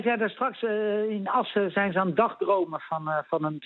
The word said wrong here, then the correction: dagdromen: En ik denk dat je de klemtoon net verkeerd legt. Het is meer dagdromen.